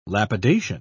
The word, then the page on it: lapidation: British English